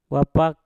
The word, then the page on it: wapak: mpur